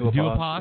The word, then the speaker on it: Duhaupas: But Gerald Big Baby Miller and Johan Duopas, am I saying that right? Or is it Duhapas? – Yeah. Duopas. That's how I've always heard that.